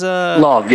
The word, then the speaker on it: Lauv: Is that how you pronounce his name, by the way? Love, L-A-U-V. The guy, he sang BTS's... – Love, yeah, L-A-U-V.